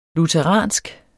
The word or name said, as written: lutheransk